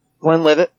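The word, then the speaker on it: Glenlivet: Is it Glenn LeVay? Glenn Livett? How do you say that? – Glenn Livett.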